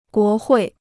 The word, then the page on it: 国会: guó huì